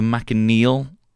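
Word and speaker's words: manchineel: Having said that, have you heard of the McNeil tree? – No, tell me more. – The McNeil tree, not Irish, it's spelled Machine Neil, is a member of the Spurge family, the world's most dangerous tree in the Florida Everglades.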